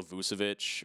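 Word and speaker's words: Vučević: DeMar DeRozan, Zach LaVine, Nikola Vucevic.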